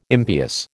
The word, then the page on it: impious: American English